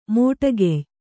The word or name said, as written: ಮೋಟಕೆಯ್